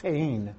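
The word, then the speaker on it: chen (חן): It's the word chen. And that's another one that we Americans have a very hard time pronouncing.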